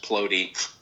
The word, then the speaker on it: Plote: You know, my handle is, and I didn't correct you earlier, but my last name is actually pronounced Plote.